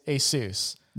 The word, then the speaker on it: Asus: The official way to pronounce it is Asus. – Wow. – Like Dr. Get Seuss.